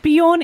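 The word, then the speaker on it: Björn: Bjorn is the correct pronunciation.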